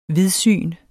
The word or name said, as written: vidsyn